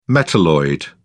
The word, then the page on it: metalloid: British English